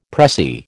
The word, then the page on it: pressie: American English